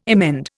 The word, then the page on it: emend: Indian